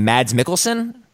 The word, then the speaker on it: Mads Mikkelsen: how do you say his name, Mads Mikkelsen? – Yes, that's right.